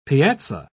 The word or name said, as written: piazza